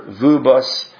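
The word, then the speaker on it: Vööbus: It's a V with two O's, and they're both umlauted O's, so it's Arthur Vuubas.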